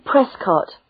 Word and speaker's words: Prescott: I'm Mary Prescott. – Can you spell that? – Yes, it's P P-R-E-S-C-O-T-T.